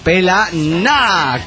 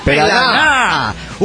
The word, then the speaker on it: Pelaná: Se dice Pelaná, con un acento en la última sílaba detonando insulto. – Pelaná.